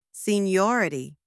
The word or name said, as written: seniority